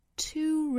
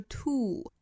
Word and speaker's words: two: Two roads. 那首先我们要注意 two 这个单词的发音。大家不要把它发成一个直上直下的一个呜的发音，不是 to，要注意一个口型的过渡，也就是从辅音 t 的口型去过渡到后面的长音呜。